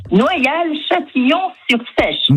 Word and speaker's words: Noyal-Châtillon-sur-Seiche: Noyal-Châtillon-sur-Seiche.